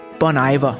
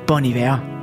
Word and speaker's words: Bon Iver: Bon Iver. Og ja, det kan jo i øvrigt udtales på flere forskellige måder. De fleste amerikanere, de siger Bon Iver. Britterne siger Bon Iver.